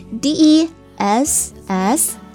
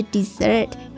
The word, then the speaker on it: dessert: 好了，来讲到第二个单词，跟它长得很像的，多了一个 s 的，来拼写一下 desert， D-E S-S-E-R-T，两个 S 哈，它表示甜点，相同的读音是 dessert， dessert。